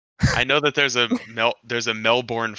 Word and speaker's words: Melbourne: Is it Melbourne? – No. That's far too many vowels. Just Melbourne.